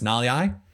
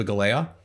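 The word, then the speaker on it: Galeai: It's Naliai. Tipa Naliai. It looks like Tipa Galea. It's tough, but it's Naliai.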